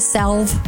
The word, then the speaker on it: salve: Are you sure that you don't pronounce it salve? – You don't pronounce it salve. Nobody pronounces the L in S-A-L-V-E.